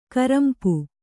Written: ಕರಂಪು

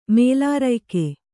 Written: ಮೇಲಾರಯ್ಕೆ

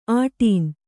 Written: ಆಟೀನ್

